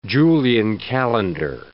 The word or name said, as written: Julian calendar